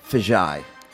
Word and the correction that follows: Fiji: Fiji. – What's the last one? – Fijai. – No, I think you said it wrong again. – Artesian Fijai. – I think Fiji.